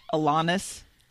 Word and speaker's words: Alanis: That's Alanis. Alanis. – Alanis. – Morissette. Alanis. – Uh, Rhymes with Adonis.